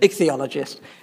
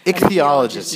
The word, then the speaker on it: ichthyologist: How is it that you got to be what do you call an ichthyologist, ethnomologist, I can't even pronounce it. – Ichthyologist. – Ichthyologist, yes.